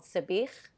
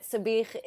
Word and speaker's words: sabich: So my go-to for that is something called sabich. And I'm not choking, but that's literally the pronunciation. It's with a at the end, so apologies. But sabich